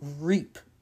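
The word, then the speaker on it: Ryp: Rape. – Hey! Nope, it's not pronounced that. – I don't know how to pronounce that. Rip? – Reap. – Reap? Reap. Juan Jose Reap.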